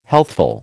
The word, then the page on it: healthful: American English